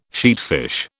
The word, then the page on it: sheatfish: British English